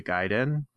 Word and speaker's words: Gaiden: Now, proper pronunciation, if you want to be pretentious, is Ninja Gaiden, but it's, you know, everyone called it Ninja Gaiden growing up.